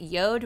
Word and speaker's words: Jord: Real quick, we wanted to tell you about our friends at Yod Watches. It's spelled J-O-R-D,